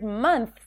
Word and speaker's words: months: The first hack is that you don't have to pronounce the TH in the words clothes and months. This transition is very challenging for non-native speakers who don't have the TH sound in their language. So to pronounce the word months, we think that we need to transition between the TH and the S, months. But in fact, people tend to drop the TH here and just say months.